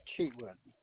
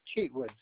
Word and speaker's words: Cheatwood: Philip Cheatwood. And I initially purchased something for $5.95. – Can you spell that first name for me? – Philip. P-H-I-L-I-P. Philip. – And that last name? – Cheatwood. C-H-E-A-T-W-O-O-D.